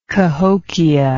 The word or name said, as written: cahokia